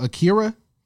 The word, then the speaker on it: Akira: And they took, correct me if I'm wrong, Akira.